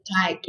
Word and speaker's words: Tycke: He's Sheldon Tyke is his last name. – Sheldon Pike? – Tyke, like T-Y-C-K-E. So it's like Tyke, bike, you know, rhyming with that. – Okay. – The T. Like little Tykes.